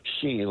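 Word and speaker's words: Xi: Hey, Tom, while I have you on the air, Chinese word that is spelled X-I is pronounced she, like the English word she, as in he, she, it, not she.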